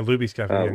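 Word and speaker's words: Luby's: And you had the guy who was at Lubby's Cafe, if I'm pronouncing it right. – Yeah, Lubby's Cafe. – Lubby's. Yep.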